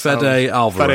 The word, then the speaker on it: Fede Alvarez: That's Fede Alvarez.